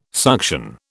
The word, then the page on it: suction: British English